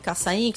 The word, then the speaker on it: Cassaim: Só colocar com o meu sobrenome, Cassaim, que só tem eu e meus irmãos em Fortaleza, que é Cassaim. E obrigado, Sábio, por ter pronunciado da forma correta, que não é todo mundo que pronuncia certo, não.